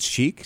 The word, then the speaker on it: Chic: That's Sheikh, C-H-I-C.